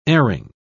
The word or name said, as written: erring